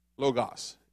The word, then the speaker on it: logos: So, that word, I hate to say it like that, that English word for word, the Greek is logos, L-O-G-O-S, logos.